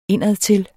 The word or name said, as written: indadtil